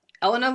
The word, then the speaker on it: Eleanor: And her name is Eleanor, but they pronounce it Elena. That's how British people say it.